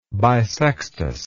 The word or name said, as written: bissextus